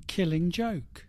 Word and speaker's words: Killing Joke: How do you say it? – Killing Joke.